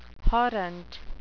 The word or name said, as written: horrent